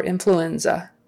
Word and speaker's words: influenza: Influenza. Influenza. Stress on the N. Influenza. Second to last syllable.